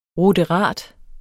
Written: ruderat

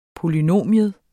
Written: polynomium

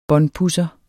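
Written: båndpudser

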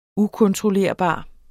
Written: ukontrollerbar

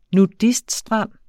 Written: nudiststrand

